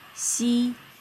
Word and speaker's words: x: Then we have letter X, which is pronounced as xi, xi, like xie xie, xi.